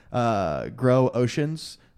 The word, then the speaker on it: Groshans: I think Groshans is how it's pronounced. Groshans.